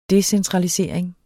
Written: decentralisering

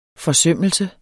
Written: forsømmelse

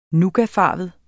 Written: nougatfarvet

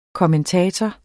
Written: KOMMENTATORER

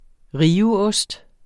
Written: riveost

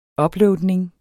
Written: uploadning

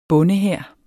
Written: bondehær

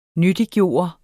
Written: nyttiggøre

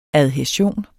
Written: adhæsion

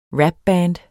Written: rapband

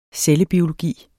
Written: cellebiologi

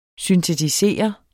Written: syntetisere

